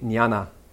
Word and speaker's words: jnana: So amongst the Hindus, the yogis of India, they studied jnana yoga. Jnana means knowledge, of course. And notice that the prefix jn has the same pronunciation as gnosis, as an N sound, as you pronounce in Spanish.